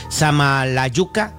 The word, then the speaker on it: Samalayuca: Déjame ver si lo dije bien. Samalayuca, Chihuahua, sí.